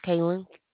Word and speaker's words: Kaylen: My name is Kaylin. – How do you spell it, please? – K-A-Y-L-E-N. – K-A-Y-L-E-N. L-E-N? – Yes, ma'am.